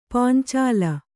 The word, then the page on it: ಪಂಚಾಲ: pancāla